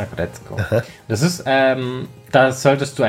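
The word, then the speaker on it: Aggretsuko: Das Ding ist auch auf Netflix, heißt Agretzko. Mhm. Geht gerade voll durch die Wand. Also das schreibt man Agretzuko, aber man spricht es Agretzko aus. – Agretzko.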